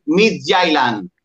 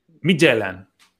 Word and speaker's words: Midtjylland: Eh, tú contra los mejores danés, ¿cómo se dice? – Midtjylland. Midtjylland, perfecto.